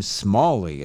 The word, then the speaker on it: Smallie: I believe they pronounce the name Smallie. It's S-M-A-L-L-I-E.